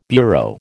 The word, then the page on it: bureau: American English